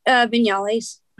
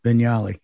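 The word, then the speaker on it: Viñales: and in Versalles, is that how you pronounce it, Versalles, Cuba? – Vinales. – Vinales.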